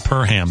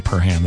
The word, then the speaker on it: Perham: And if you're in Perham, pronounce it Perham. They love it. They love it when you say Perham there.